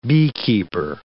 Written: beekeeper

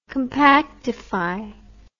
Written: compactification

